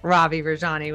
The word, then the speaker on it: Ravi Rajani: I have not just Ravi Rajani with me, but the Ravi Rajani with me.